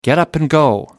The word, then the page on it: get-up-and-go: American English